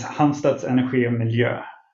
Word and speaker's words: Halmstad Energi och Miljö: Well, the pronunciation is Halmstad Energi och Miljö.